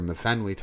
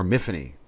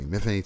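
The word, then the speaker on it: Myfanwy: Because The Rook, the main character, Mifanwy Thomas, or Miffany, excuse me, Miffany Thomas is how they pronounce it. They make a big point of it in the book. – Okay. – You know, it's pronounced like Tiffany, Miffany.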